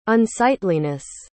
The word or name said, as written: unsightliness